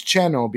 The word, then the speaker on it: Xeno: The pronunciation for the game in Spanish where it was created is Cheno, because that's the pronunciation for South in Mexico.